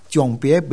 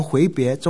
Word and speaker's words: 迥别: 你试试看，牧师、执事、长老、领会的，你读圣经，迥别没念成回别，中文程度不错了。